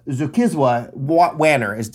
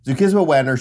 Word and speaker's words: Zukiswa Wanner: The South African writer Zukiswa Wanner. Am I pronouncing that right? – Yes. – Zukiswa Wanner,